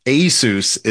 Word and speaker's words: ASUS: There's, like, I think four official, semi-official pronunciations in English, but I think ASUS is the most correct pronunciation, if you want to call it that.